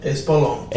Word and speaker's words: Espolón: All right, so I hope I say this right. The Espolón? Did I say that right? – Espolón. – Espolón.